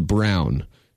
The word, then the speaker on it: Braun: And again, as I said, entering the draft, I'm out on him because it's spelled Christian Braun, B-R-A-U-N, but it's pronounced Brown.